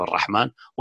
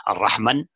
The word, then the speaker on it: ar-Rahman: So this is deliberate, and also writing of Ar-Rahman, which is universal even outside the Quran, without Alif, although it's never pronounced Ar-Rahman, it's pronounced Ar-Rahman.